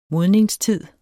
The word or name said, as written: modningstid